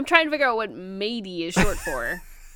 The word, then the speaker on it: Madie: Yeah, it's not Maddie, it's Mady. – It's spelled M-A-D-I-E, but it's pronounced like she's a fucking pirate.